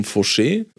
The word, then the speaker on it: Faucher: und woran man auch arbeiten kann, sind schöne winterarktische Szenen, wie äh, ein neues Tutorial von William Fauché, äh, geschrieben William Faucher, aber immer wieder ausgesprochen William Fauché.